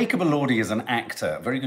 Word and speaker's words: Jacob Elordi: Let's have a chat about Jacob Elordi.